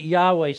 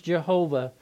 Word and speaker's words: Yahweh: We pronounce it Yahweh. Some call it in English Jehovah.